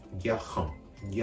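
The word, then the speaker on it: garant: 外を震わせる振動音摩擦音として説明されますつまりこのうがいをするような仕草という仕草ですそこにこのハヒフヘホのハ行の音を足してあげることでフランス語のこのるの発音になるわけです。この場合では、は、いう形で、ギャハン、ギャハンと発音すればあるの発音になります。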